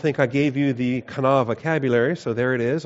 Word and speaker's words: qanah: But the verb is kana, Q-A-N-A-H is how you spell it when you transliterate it into the English.